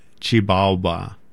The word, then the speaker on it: Xibalba: So within Guatemala, there's a jewelry artisan shop called Chibalba. And it's spelled Xibalba, Chibalba.